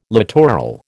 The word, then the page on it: littoral: American English